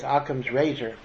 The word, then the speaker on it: Occam's razor: But again, part of why, you know, in science you have a very similar concept of called Occam's razor, that's how you pronounce it, in which when you have multiple explanations for a phenomenon, you try to seek the simplest explanation that creates the least complexity.